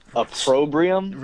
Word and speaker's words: opprobrium: Opprobrium? – Repeat. You actually pronounced that perfectly. – Opprobrium. – Opprobrium. – A-P-R-O-B-I-U-M.